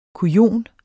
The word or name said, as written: kujon